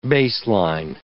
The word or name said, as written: baseline